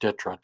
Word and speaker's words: DTRA: It's known as, the acronym is DTRA. It's pronounced DITRA, Defense Threat Reduction Agency.